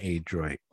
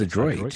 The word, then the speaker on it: Adroit: It's not Adroit. It's Adroit. – So it is Adroit. – Let me hear this. I see it's not a long A. – Adroit. – It's Adroit. – Okay, so the A is more, what do you call it? Like Adroit. – It's a softer, it's a short vowel sound.